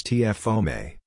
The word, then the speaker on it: TFOM: TFOM, transcription factor OME, pronounced as TFOME,